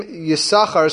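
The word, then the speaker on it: Yisachar (יששכר): that the name Yisachar is pronounced as if there's only one sin. It's not Yisachar, the name is Yisachar.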